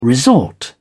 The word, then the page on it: resort: British English